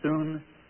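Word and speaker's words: soon (σύν): In English letters, it would be S-U-N, pronounced soon.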